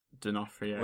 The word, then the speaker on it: D'Onofrio: But I am definitely all about the Vincent D'Onofrio. Vincent D'Onofrio.